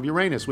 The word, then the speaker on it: Uranus: Uranus, which is pronounced the way any self-respecting class clown would say it.